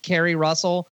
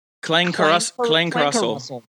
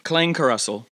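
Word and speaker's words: Klangkarussell: It looks like it should be pronounced Carrie Russell, but it's like Klang Carussel. Yeah. – Say it again. – Klang Carussel. – There you go.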